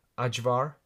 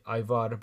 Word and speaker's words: ajvar: If you have a foreign shop near you, I suggest going there and looking for this thing called ajvar. So I call it ivar, but it's pronounced ajvar.